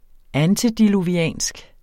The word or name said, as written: antediluviansk